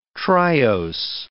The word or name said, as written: triose